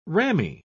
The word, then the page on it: ramie: British English